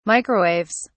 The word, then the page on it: microwaves: British English